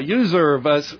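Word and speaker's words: Uzurv: Yeah, Userve, I like to say it rhymes with reserve, right?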